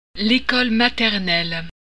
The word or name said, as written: maternelle